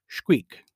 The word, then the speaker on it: Xquic: Now the X at the beginning or end of any Mayan word is always pronounced with a sh type sound, so it looks to me like her name should be pronounced Shqeek.